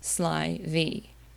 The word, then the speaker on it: slithy: Pronounce slithy as if it were the two words, sly-thee.